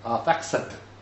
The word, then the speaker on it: Arphaxad: In fact, he was a brother of Arphaxad, sometimes pronounced Arphaxad, because there's an X in there, but the X is usually pronounced with a Z. Not all the time.